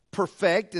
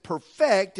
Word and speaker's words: perfect: When used as an adjective, the word is pronounced perfect, and that means to being entirely without fault or defect. That means that it's flawless. Now, when we use it as a verb, we pronounce it perfect, and that means something a little different.